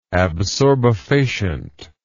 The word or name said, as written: absorbefacient